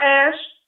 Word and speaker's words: Ashe: Again, my name is Sarah Ash. On Facebook, it's S-A-R-A-H. Ash is A-S-H-E. The E is silent. Ash, A-S-H-E.